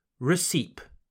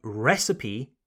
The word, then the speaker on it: recipe: I very often hear French people say recipe or recipe. But no, that's incorrect. I know that usually when a word in English finishes with an E, E en français, we don't pronounce the E. Like we say make, not maki, don't we? Well, the reason why recipe is an exception goes back to what I mentioned earlier recipe is not originally an English word.